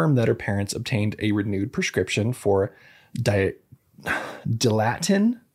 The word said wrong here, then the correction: Dilantin: I think is how you say it. Dilatin, whatever she was taking before. She's still on that. Okay, Dilantin. There we go.